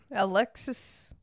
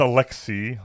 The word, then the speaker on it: Aleksey: Yeah, it's Alexei, like A-L-E-K-S-E-Y, Alexei.